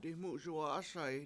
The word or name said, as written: dimUZuwa7ASa7i